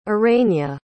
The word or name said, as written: urena